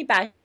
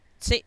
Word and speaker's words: Basia: You can call me Basia. Like Basha.